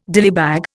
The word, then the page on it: dillybag: Indian